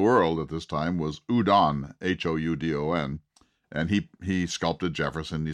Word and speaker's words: Houdon: You know, if you want to see his work, just Google it, Houdon, pronounced Houdon.